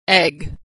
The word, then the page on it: egg: g